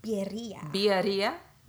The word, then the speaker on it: birria: Like, for real, if you ever get a chance to have, how do you say it, birria? – Birria.